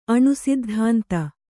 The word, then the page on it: ಅಣುಸಿದ್ಧಾಂತ: aṇu siddhānta